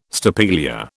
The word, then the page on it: stapelia: British English